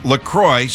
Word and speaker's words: LaCroix: They say it's LaCroix, so it rhymes with enjoy.